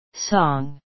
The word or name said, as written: song